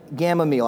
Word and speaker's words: Gamaliel: And his name was, I'm going to pronounce this right, Gamaliel.